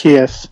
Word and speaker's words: Zaccheaus: last Zaccheaus. Name. – Zaccheaus, who gets his one catch for 93 yards and a touchdown.